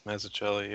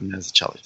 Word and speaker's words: Mazzucchelli: And David, I don't know how to pronounce his last name, Mazzucchelli? – Mazzucchelli, yeah. – Okay, it's David Mazzucchelli, thank you.